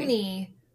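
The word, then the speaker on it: Owney: It's Oney, but I want to say Owen because...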